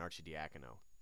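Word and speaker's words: Arcidiacono: 70 points between Bell, Hilliard, and Archidiakono. – Well, first of all, congratulations on pronouncing his name correctly.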